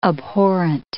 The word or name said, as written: abhorrent